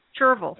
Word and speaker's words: chervil: And then chevrel, I don't know if that's pronounced correctly, which is a companion to radishes for improved chervil. Yeah. – Chervil. And it sounds like a gerbil. So it rhymes. We could create a poem.